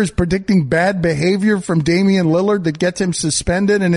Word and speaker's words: Damian Lillard: We have Damian Lillard. Am I correct? – Yes, that's how you pronounce it.